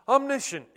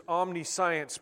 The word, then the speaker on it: omniscient: Omniscient, yeah. I like to think of it as omniscience because that helps me to spell it, but it's actually pronounced omniscient, meaning all-knowing.